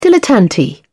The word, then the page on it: dilettante: British English